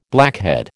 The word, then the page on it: blackhead: American English